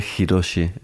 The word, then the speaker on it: Hiroshi: Miguel Hiroshi es mi nombre.